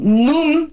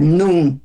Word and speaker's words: nun: Sí, y miren, NUM se pronuncia esta letra, NUM, así, N-U-N, NUM.